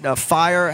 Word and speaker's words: FIRE: But he's a free speech advocate, FIRE, as the acronym is pronounced.